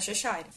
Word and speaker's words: Cheshire: Porque o Shire do final, na Inglaterra, em alguns lugares, é pronunciado Shire. Então é Cheshire.